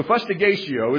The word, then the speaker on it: fustigatio: The first one was, the word is fustigatio. I had to actually look up how to pronounce it, because I'm not a Latin speaker. So fustigatio is the word, and it referred to the lesser of the beatings.